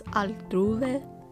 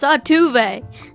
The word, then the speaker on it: Altuve: And the little bear's like, "You sure it's not pronounced Altuve?" He's like, "No, it's Altuve."